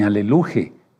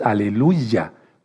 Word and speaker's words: aleluya: Por eso nosotros no decimos aleluya, no, porque es ni aleluje, no, es aleluya, porque es el nombre del Eterno.